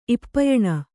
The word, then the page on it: ಇಪ್ಪೆಯೆಣ್ಣೆ: ippeyeṇṇe